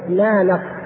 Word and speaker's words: نانق: هذا الرجل اسمه نانق أو نانق، نانق نانق، نون ألف نون قاف، أحيانا يحط بدل القاف كاف عليها إشارة كده، يعني نانق نانق باللغة النطق الهندي نانق.